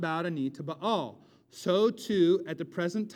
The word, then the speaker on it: Baal: He does say, however, I've saved 7,000 who have not bowed a knee to the Baals, B-A-apostrophe-A-L, which pronounces weird in English, which you pronounce weird in English, Baal.